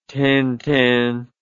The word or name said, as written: tintin